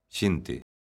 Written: xente

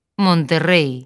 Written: Monterrei

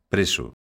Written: preso

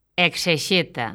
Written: exexeta

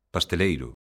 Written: pasteleiro

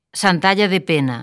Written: Santalla de Pena

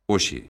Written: hoxe

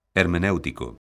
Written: hermenéutico

